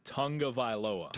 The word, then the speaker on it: Tagovailoa: That's why I think Tua Tagliavoa. How did our friend Eli Gold tell us? – No. Yeah, you're butchering it. – Tagliavoa? – No. Tagovailoa. – Tagovailoa? – Tagovailoa. – Tua. – Tua Tagovailoa.